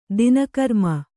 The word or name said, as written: ದಿನಕರ್ಮ